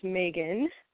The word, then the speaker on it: Megan: My name is Megan.